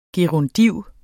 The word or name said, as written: gerundiv